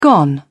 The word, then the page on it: gone: British English